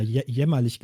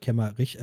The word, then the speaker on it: Kemmerich: Ähm, ähm, ah ja, Entschuldigung, liebe Zuhörer, ich habe den Namen falsch gesagt. Ich habe immer jämmerlich gesagt anstatt Kemmerich.